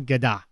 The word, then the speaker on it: Gadot: I think it's a soft T for the record. It's Gal Gadot. – Gal Gadot. – Something. – The O just keeps on. – You're supposed to pronounce the T, but softly.